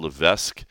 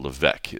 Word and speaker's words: Levesque: If you look at the way it's spelled, I always thought it was Paul Levesque, but it's Paul Levesque. It's the S is silent.